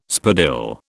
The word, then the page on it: spadille: British English